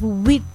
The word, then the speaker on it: huit: S-E-P-T. Then we have wit. – Wit. – H-U-I-T. Wit. – Wit. – Then we have neuf. – Neuf. – N-E-U-F.